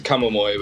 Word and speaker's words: Akamarmoi: Pretty close. It's a Akamarmoi, but that's the standard pronunciation. You're right.